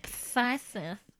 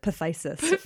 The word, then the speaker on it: phthisis: P-H-T-H-I-S-I-S. Yeah, that's a PH and a TH at the beginning. – Thysis. – I had to look it up. I'm pretty sure it's Thysis. – You always look up how to pronounce things and I don't. So probably people are like, oh God, Aaron, with the pronunciation. – Also, it just seemed too ridiculous to have to pronounce it Pathysis.